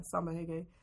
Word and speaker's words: Reggae: In Brazil, the R's are H's, so yeah, Samba Hege.